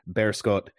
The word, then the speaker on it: Beerschot: He joins after a year and a half at the helm of Beerschot in Belgium. I confirmed with a Dutch speaking friend that is in fact the pronunciation of this club in Antwerp.